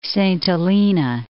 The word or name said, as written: Saint Helena